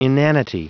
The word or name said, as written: inanity